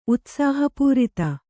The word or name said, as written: ಉತ್ಸಾಹಪೂರಿತ